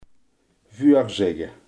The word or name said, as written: voorzeggen